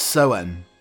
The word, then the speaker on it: Samhain: Or, again, I pronounce it completely incorrectly, it's Soen, but it's spelt Samhain.